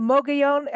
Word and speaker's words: Mogollon: And is it the Mogollon? Am I saying this right yet? – Yes.